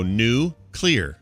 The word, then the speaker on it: nuclear: You look at the word N-U-C-L-E-A-R. You go new clear. You just pronounce it. Nuclear.